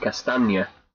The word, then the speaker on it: Castagne: I think I was watching the game last night and they kept saying Castagne. So, more like lasagna.